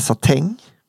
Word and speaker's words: satin: Eller satäng. – Satin, men allt som slutar på ien ska uttalas äng.